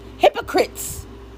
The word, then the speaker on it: Hippocrates: Hypocrites. That's how you say it, hypocrites.